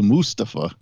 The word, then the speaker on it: Mustafa: And he was saying, yeah, it's the correct way to say it. It's Mustafa. – Mustafa.